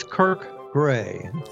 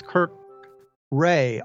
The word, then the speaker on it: Kirk Gray: It if you say my name fast, people think it's Kirk Ray, R-A-Y.